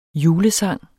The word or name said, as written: julesang